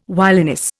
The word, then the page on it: wiliness: Indian